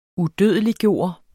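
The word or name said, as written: UDØDELIGGJORTE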